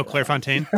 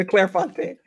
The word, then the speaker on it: Clairefontaine: Oh, Clairefontaine? – Yeah, the Clairefontaine. – Yeah. – I was going to roll my R, but I deferred. The Clairefontaine notebooks, what I used in college.